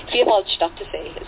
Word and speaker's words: Vierwaldstättersee: They call it the Four Forest and State Sea because it's Vierwaldstättersee is how you say it in German,